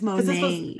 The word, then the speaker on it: Monat: Is it Monat or Monet? – It's Monet. – It's supposed to be modern in nature. That's what it's Monet, but I say Monat because that's how it's spelled.